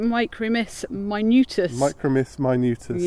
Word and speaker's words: Micromys minutus: How do you pronounce it? Micromys minutus. – Yeah. It's a perfect Latin name.